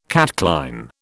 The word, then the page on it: catchline: British English